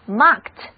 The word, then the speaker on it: marked: 注意字母组合 ed 在这里的读音是清辅音 t，整个单词读作 marked.